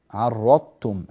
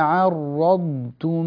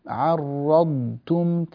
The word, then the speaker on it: عرضتم: الخطأ الذي قد يقع في مثل كلمة فرضتم، كلمة عرضتم، أن تتحول الضاد إلى طاء، فرضتم عرضتم، هذا غير صحيح.